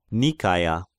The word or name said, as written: Nikaia